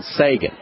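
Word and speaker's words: Seigen: One I'd like to mention today is a product called Sagan.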